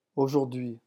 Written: aujourdhui

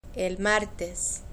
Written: el martes

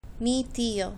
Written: mi tio

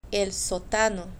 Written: el sotano

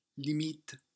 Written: limite

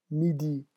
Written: midi

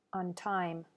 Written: on time

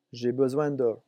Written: j'ai besoin de

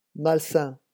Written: malsain

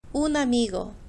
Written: un amigo

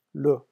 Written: l

